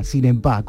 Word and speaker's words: Sinebá: Así como suena, Sinebá, con doble B y acento en la última A, ya que no sabéis cuál era su último apellido.